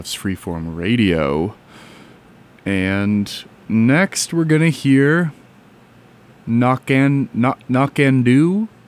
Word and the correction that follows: No Can Do: Oh, No Can Do. That's how you pronounce it. You'll see what I mean if you look at like how it's spelled when I put it on the stream by Michael Hurley.